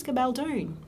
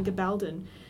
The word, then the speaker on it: Gabaldon: Apparently it's Gabaldon. – Gabaldon, and yeah, a classic. – I YouTubed it to see how it's pronounced. This entire time I've been saying Gabaldon.